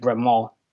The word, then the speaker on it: Bremont: I say Bremont but it's actually it is actually Bremont you don't. It's a silent T.